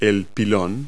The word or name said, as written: pylon